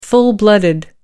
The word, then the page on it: full-blooded: American English